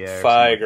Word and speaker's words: Feig: Feig or whatever, the next trailer could be really good.